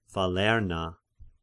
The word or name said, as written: Falerna